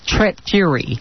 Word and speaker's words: Tret Fury: and Deborah Bell interviewed women's music icon Tret Fury. Is that pronounced correctly? – Tret Fury. – Mm-hmm. – Just like the Furies, but it actually means fir tree, her last name.